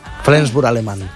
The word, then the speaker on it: Flensburg: Flensburg. ¿Lo digo bien? – Sí. – Flensburg alemán.